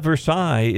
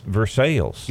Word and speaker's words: Versailles: In Kentucky, it's not Versailles, it's Versailles. – Versailles. Mm. – They say Versailles, Kentucky. And if you say Versailles, they know you ain't from around there.